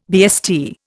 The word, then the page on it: BST: Indian